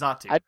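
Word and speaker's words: Xatu: So you can pronounce Zatu correctly because it has a friggin' X on the front, but you can't pronounce Alolamola correctly. – I mean, what was I supposed to say? Zatu. – X2 or something? X2. I don't know. – Exatu? I don't know. Like, it's Zatu.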